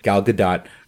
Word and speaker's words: Gal Gadot: because I guess that's apparently the proper way to say it, Gal Gadot, and I photoshopped like a very bad Wonder Woman outfit on her, and of course that's when she was a lot thinner.